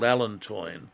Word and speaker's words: allantoin: allantoin. Now, I'll spell it for you. A-L-L-A-N-T-O-I-N. Allantoin.